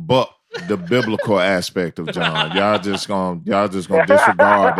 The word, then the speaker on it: Jon: But on social media, at John Glass _ J-O-N, no H's.